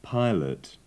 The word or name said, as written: pilot